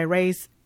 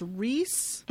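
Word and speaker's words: Rhys Ifans: I think it's Reese, mm-hmm. – but I don't know how to say the last name. I looked up the pronunciation of his name, and it's Reese Ifans.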